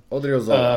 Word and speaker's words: Odriozola: still don't know how to say his last name, Odriozola. Mm. Mm-hmm. I should know this because I bought him in FIFA yeah. months ago. – Odriozola. Um, that was good.